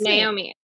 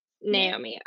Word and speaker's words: Naomi: Yeah, it's Naomi. Naomi.